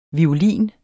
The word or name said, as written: violin